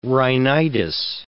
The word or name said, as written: rhinitis